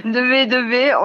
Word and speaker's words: 2B2B: Ça se prononce peut-être à l'anglaise, hein, 2B2B ? – Non, on reste bien. 2B2B, on a essayé hein, plusieurs fois, mais non.